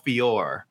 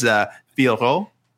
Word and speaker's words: Fiorot: But it is Fioro.